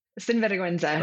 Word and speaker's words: sinvergüenza: Sinvergüenza.